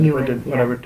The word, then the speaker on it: Ewan: Ewan.